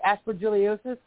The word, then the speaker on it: aspergillosis: Isn't that right? Aspergillosis? – Yeah, aspergillosis. Yeah, that's a fungus.